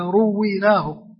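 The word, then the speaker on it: رُوِّيناه: قال حديث حسن، هذه تنطق هكذا، رويناه بالتشديد مبنية للمجهول، حديث حسن رويناه، فإذا جاء في هذا الكتاب أو في غير هذه العبارة فتنطق بهذا النطق.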